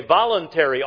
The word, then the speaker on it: voluntary: But this sacrifice of thanksgiving was a voluntary, I put the emphasis on the wrong part of the word. It was a voluntary offering, this sacrifice was.